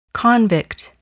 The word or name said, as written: convict